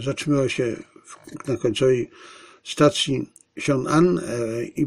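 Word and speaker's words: Xiong'an: Rozpoczęto budowę linii kolejowej Pekin-Siong'an. Tak się to wymawia. I zacytuję te cztery zdania. Kolej międzymiastowa łącząca Pekin z Siong'an, New Area rozpoczęto budowę tej kolejki 28 lutego.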